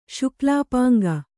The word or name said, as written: ಶುಕ್ಲಾಪಾಂಗ